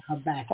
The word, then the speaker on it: Habakkuk: So the verses that we had to read to prepare comes from the book Habakkuk I think I'm pronouncing that right. – Yeah, Habakkuk. – Habakkuk, yes, chapter 2, and it starts at verse 1.